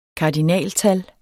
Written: kardinaltal

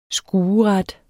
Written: skueret

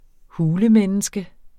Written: hulemenneske

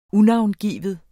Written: unavngiven

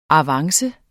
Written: avance

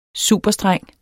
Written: superstreng

